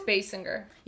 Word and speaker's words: Basinger: I think it's Basinger. – Yeah. – Yeah. Like bass singer type.